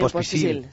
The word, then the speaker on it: Pospisil: Pospisil, ¿cómo se diga? ¿Cómo se dice? Miriam, ponte, ayúdame. – Lo has dicho bien, Pospisil.